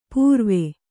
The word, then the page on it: ಪುರಾವೆ: purāve